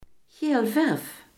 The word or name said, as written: geelzucht